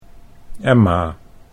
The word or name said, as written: Emma